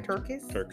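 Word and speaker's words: TerKeurst: I have a book right now, I cannot pronounce her last name, Made to Crave, by Lisa Turkus. I think I did it right. Turkus.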